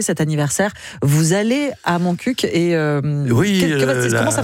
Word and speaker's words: Montcuq: Dans le Petit Rapporteur, voilà ce reportage sur le village de Montcuq. Il faut prononcer le cul à la fin. – Oui, comme ils disent, on dit un coq, on ne dit pas un co, donc on dit mon coq.